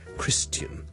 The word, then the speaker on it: Christian: 而基督徒 Christian 咧，我哋上次讲咗，亦都有一啲又系老一辈嘅人咧，可能会读成咧 Christian 嗰个发音好似 tian。人咁，你当系好似人啦，跟住前面加个好轻嘅 t，OK。